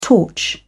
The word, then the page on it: torch: British English